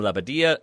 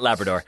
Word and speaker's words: Labbadia: However, Hertha coach Bruno Labbadia hinted that's how you that pronounce it. No one should be pronouncing it the other way. – Nah, not Labrador.